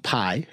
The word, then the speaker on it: Pai: And we do have a special guest with us, a Mr. Ajit Pai.